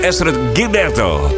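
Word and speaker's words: Astrud Gilberto: Ik heb ze echt neiging om mijn naam verkeerd te uitspreken. Ik zal het nog een keer proberen. Ezra Gilberto. Want zo moet je het uitspreken.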